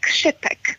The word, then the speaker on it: Čipek: Men ska man vara väldigt petig så ska det uttalas Čipek.